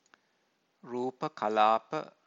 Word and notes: rupa-kalapa: Pali

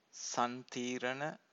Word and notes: santirana: Pali

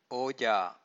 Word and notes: oja: Pali

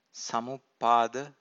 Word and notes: samuppada: Pali

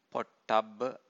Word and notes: pottabba: Pali

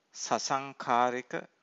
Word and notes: sasankharika: Pali

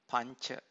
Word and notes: panca: Pali

